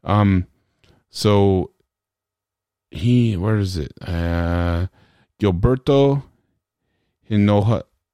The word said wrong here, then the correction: Hinojosa: Hino Josa, sorry I butchered your name.